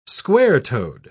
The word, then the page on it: square-toed: British English